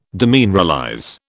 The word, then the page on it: demineralize: British English